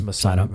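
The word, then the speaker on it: Messari: It's Massari, by the way. That's how you pronounce it.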